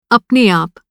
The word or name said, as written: अपने आप